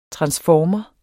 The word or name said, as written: transformere